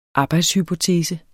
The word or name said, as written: arbejdshypotese